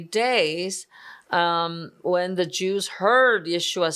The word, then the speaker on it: Yeshua: Jesus in English, but here we also call his name in the original pronunciation, Yeshua.